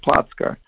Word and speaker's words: Plotzker: We just added an ER and Americanized it. And that's why it sounds like Plotzker. But the original name of the city is P-L-O-C-K, and it's pronounced Płock.